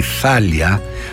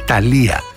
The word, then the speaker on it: Θάλια: Τα χειροκροτήματα δεν ήταν για το γεγονός αυτό, αλλά ήταν για μια σπουδαία μεξικάνα τραγουδίστρια, τη Θάλια, την οποία τη λένε Ταλία, γιατί δεν προφέρουν το TAFH που είναι θήτα. Το κανονικό της όνομα είναι λοιπόν Θάλια, και να ακούσουμε ένα ωραίο τραγούδι από αυτήν.